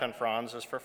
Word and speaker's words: Frans: It's spelled F-R-A-N-S, Franz.